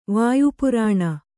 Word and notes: ವಾಯುಪುರಾಣ: vāyu Purāṇa

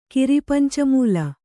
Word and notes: ಕಿರಿಪಂಚಮೂಲ: kannada

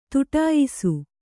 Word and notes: ಥಟ್ಟಯಿಸು: thaṭṭayisu

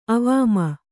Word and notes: ಅವಾಮ: kannada

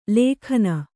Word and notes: ಲೇಖನ: lēkhana

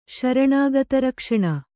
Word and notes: ಶರಣಾಗತರಕ್ಷಣೆ: Kannada

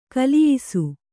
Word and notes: ಕಲಿಯಿಸು: kaliyisu

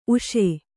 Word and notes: ಊಸು: ūsu

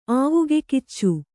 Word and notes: ಆವಗೆಗಿಚ್ಚು: āvagegiccu

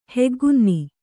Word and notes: ಹೆಗ್ಗುನ್ನಿ: kannada

